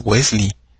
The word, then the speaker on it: Wesley: O sea, es Wesley. Así se pronuncia. Entonces, lo que termina con L-E-Y se pronuncia Lee. O sea, tráguense la E. O sea, no la pronuncien.